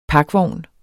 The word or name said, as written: pakvogn